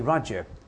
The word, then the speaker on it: Raja: Kali Raja Raja 是王了， R-A-J-A Raja， It pronounces Raja。 Raja 為什麼王？所以有些印度的，你去到印度的地方，他叫 Raja Theatre，就是王家戲院。 Raja 就是王了，他叫做 Kali Raja，即是說歌利王。